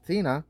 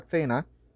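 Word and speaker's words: Thena: Tina, sería Tena.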